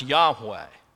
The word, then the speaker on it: Yahweh: And so there's no vowels in the Hebrew as it was originally written, but it's often been pronounced Yahweh.